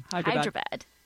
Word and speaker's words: Hyderabad: Hyderabad,